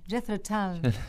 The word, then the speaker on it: Jethro Tull: Jethro Tull.